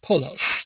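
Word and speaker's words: polos (πῶλος): In fact, these two characters are read po-ro, or would be pronounced in ancient Greek as polos, which is cognate with the English word foal.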